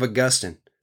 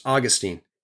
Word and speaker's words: Augustine: One of the church fathers was a man by the name of Augustine, sometimes pronounced Augustine.